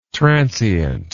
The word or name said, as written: transeunt